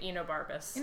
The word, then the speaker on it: Enobarbus: I found a pronunciation thing. But we think we're saying it right. Enobarbus.